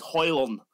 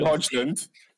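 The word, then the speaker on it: Højlund: You don't pronounce the D. It's Hoyland. – Okay. – We'll be calling him Hodgland until he's scored.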